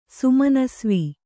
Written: ಸುಮನಸ್ವಿ

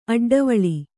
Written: ಅಡ್ಡವೇಳೆ

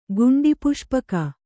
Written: ಗುಂಡಿಪುಷ್ಪಕ

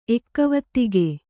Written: ಎಕ್ಕವತ್ತಿಗೆ

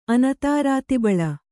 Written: ಅನತಾರಾತಿಬಳ

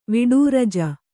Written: ವಿಡೂರಜ

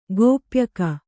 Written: ಗೋಪ್ಯಕ

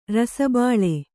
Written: ರಸಬಾಳೆ

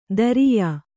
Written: ದರಿಯಾ

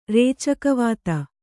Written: ರೇಚಕವಾತ

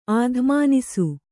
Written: ಅತಿಮಾನಸ

